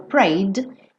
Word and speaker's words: prayed: Porque o E não é pronunciado, não vou falar prayed, então eu falo prayed.